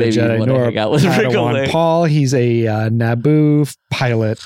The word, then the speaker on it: Padawan: Padawan? – Yeah.